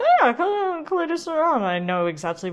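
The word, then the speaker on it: Coulée de Serrant: Your where is Coulée de Saran. That's spelled Coulée with the accents. Signal symbol over it, E space DE space Serrant. Now, as I said, it's a monopole, Coulée de Saran,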